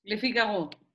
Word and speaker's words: Le Figaro: Le Figaro.